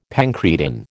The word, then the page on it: pancreatin: American English